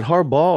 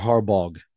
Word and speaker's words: Harbaugh: Is it Harbaugh or Harbog? Harbaugh. You sure? Yes. Positive.